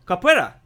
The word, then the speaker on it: Capoeira: Does Copérica count? I think I'm saying that wrong. Capoeira? The dancing fighting. Capoeira. Yeah, that's the one.